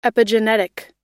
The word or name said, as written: epigenetic